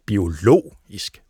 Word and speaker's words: biologisk: Og der sker netop det med udtalen, når du siger biologi, og så putter det der isk på, så bliver det til biologisk. Det ændrer trykket til en anden stavelse.